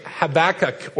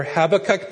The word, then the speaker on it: Habakkuk: leading into a study of the book of Habakkuk, or Habakkuk, depending on how you want to call it, how you want to pronounce it.